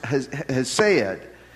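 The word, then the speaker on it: hesed (חסד): It's a word, if you were to transliterate it from Hebrew to English, it would be pronounced hesed.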